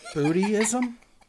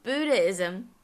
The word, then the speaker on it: Buddhism: So, Buddhism? – Just realize I said that wrong. Buddhism.